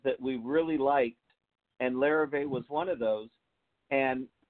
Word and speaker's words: Larrivee: Well, first I had to take 3 years of French so I could pronounce Larrivee. – Larrivee. See, I can't even pronounce it. – Larrivee. Once you can say it, then they'll endorse you.